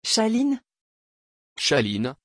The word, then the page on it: Shalyn: French